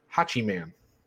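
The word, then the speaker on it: Hachiman: And he's like, obviously, this is Roderick Strong, and we got Malcolm Bivens, and you got Tyler Rust, and I believe it's pronounced Hachi Man. Is sort of like Hatchet Man, but Hachi Man type of thing is Suzuki's new name.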